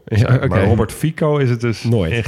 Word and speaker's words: Fico: Ja, in de Nederlandse media zal die waarschijnlijk ook Robert Fico worden genoemd. Want zo zouden wij het gewoon uitspreken. Eh, maar een C in het Slovaak spreek je uit als TS. Dus vandaar Robert Fico.